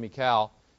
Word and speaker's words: Michal: When Saul saw and knew that the Lord was with David, and that Michal, and that is how you pronounce that name, Michal, it says here, Saul's daughter loved him.